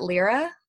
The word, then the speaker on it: Lera: I'm not really sure how to say it. L-E-R-A. Is it Lyra? – L-E-R-A. I think Lyra sounds Lyra? Right. Yeah. Yeah. – I think Lyra sounds right.